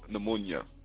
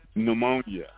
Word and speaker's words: pneumonia: Although the exact circumstances of his death are unknown, the certificate of death stated that Noble Drew Ali died from tuberculosis, pneumonia. Pneumonia. The P is silent.